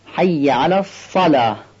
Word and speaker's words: حی علی الصلاه: اینجا بازم حی جیمی داریم که گفتیم باید به حالت عربی صحیح تلفظ کنیم، و یا مشددم داریم، حی نیست حی. و نکته سوم این که یه فتحه داره، شاید بعضی از ما اشتباهاً بگه حیه، حیه درست نیست، حیه درسته، حی علی الصلاه.